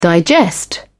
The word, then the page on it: digest: British English